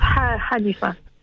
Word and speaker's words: Hanifa: Is it Hanifa or Hanifa? – It's a soft sound. It's just Hanifa.